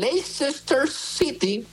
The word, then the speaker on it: Leicester City: Vámonos hasta Inglaterra, donde el Leicester City.